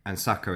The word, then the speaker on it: Saka: And Saka is another way that sake gets pronounced.